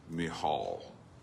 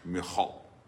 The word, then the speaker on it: Michal: And I want you to remember the background, and I actually, one pronunciation is Michal on that name, by the way. I did the deep dive, I can't help it. It looks like Michael Mitchell something, Michal, but yeah, whatever.